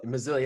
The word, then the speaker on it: Missoula: But if I could at least start with Lancaster, or you start with how do you say Missoula? – Missoula, yeah, you got it.